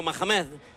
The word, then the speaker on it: Mahamed: Cuando escuchamos el silbido de Ahmad Mahamed, que es árbitro jordano. Más o menos se dice así, creo, ¿no? ¿O qué? – Sí, efectivamente, como tú bien has pronunciado, Mahamed.